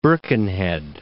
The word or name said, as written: Birkenhead